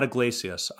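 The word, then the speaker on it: Yglesias: Yeah, Matt Iglesias.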